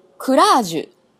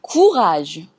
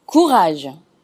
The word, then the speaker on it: courage: こちらの発音ですが、クラージュ、クラージュという音ではなくって、 courage。このうにちょっと重みを置いてあげて、 courage。